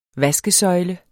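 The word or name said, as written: vaskesøjle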